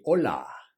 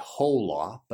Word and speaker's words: hola: So repeat after me, hola. A lot of people say hola, but there's the H in Spanish is silent, hola.